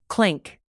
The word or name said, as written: clank